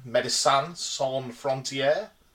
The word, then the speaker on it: Médecins Sans Frontières: And they're sometimes known by their French name, Médecins Sans Frontières.